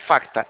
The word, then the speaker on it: Facta: É Facta, é com Facta. O C é mudo, tá?